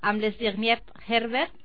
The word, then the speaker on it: Zbigniew Herbert: i començaré amb Zbigniew Herbert.